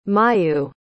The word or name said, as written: mayu